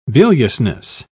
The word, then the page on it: biliousness: British English